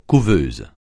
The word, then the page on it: couveuse: French